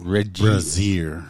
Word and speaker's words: Regier: Montana State Senator Keith is it Regier? – I don't know how to pronounce it. – Regier. – R-E-G-I-E-R. Keith Regier. It's crazy because...